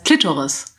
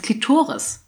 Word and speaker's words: Klitoris: Das heißt Klitoris. Ich so, will es nicht verarschen, das heißt Klitoris. Also die Betonung mehr auf dem O.